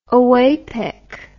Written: oapec

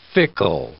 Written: fickle

